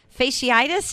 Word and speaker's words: fasciitis: We're going to be talking about plantar fasciitis. Did I say it correctly? Plantar? – Fasciitis. – Sure. Fasciitis. There you go.